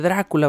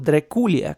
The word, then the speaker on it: Drácula: Stoker en su novela colocó el castillo de Drácula, vamos a usar, por, mi salud mental voy a usar la, la pronunciación latina, Drácula, porque si digo Dreculia cada vez me voy a hacer pelotas, ¿no?